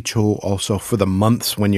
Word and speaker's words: 조: completely messing that one up now. – 초. – 초. Ah, we're talking about different syllables. – Yes. – 초. Whereas 조. – Yes. – This is 조, the softer one.